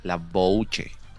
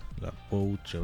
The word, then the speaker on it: La Bouche: ¿No tienes de La Bush por ahí? – ¿De La Rose? ¿Cómo se escribe? A ver. – La Bush. La Bouche. – La Bouche. A ver, vamos a ver si lo tengo.